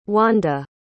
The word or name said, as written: wanda